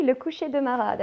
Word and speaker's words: Le Coucher de Marat: Okay, so one of your signature acts, please help me with the pronunciation here. La Couchée de Marat? – Oui, Le Coucher de Marat, that's it.